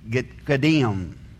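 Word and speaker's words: kadim (קדים): See, because east in Hebrew is kadim. That's the way you would pronounce kadim.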